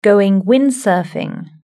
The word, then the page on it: going windsurfing: British English